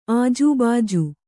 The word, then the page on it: ಆಜೂಬಾಜು: ājūbāju